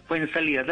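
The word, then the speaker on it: Fuenzalida: Sí, así es, fue en salida, tal cual. – Como el jugador de fútbol.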